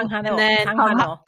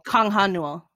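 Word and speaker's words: Kang Ha-neul: So the hero from When the Camellia Blooms is Kang Newell, right? Yeah. Is that how you say his name? – Kang Ha-Newell. – Kang Ha-Newell. – Oh, I mixed the things.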